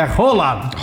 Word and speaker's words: Haaland: Só pra dizer que o Cristiano mandou mensagem dizendo o seguinte aqui: Lucas, tu só pronuncia o nome do Haaland errado. Pronuncia certo, pesquisa aí no Google. É Haaland.